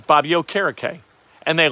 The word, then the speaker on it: Okereke: And they, when Bobby Okereke was drafted, he told everybody his last name was pronounced Okereke.